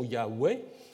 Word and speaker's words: Yahweh: disons la prononciation Yahweh est plutôt attestée en fait par des pères de l'Église, par Clément d'Alexandrie ou Origène également, qui l'attestent.